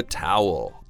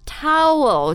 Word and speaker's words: towel: Throw in the towel. – Towel 就是毛巾，对吧、mm-hmm. Towel 这个词的发音我觉得挺难的、 mm-hmm. Towel. – Towel. – Yeah. Or favorite animal, the owl.